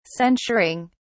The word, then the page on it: censuring: British English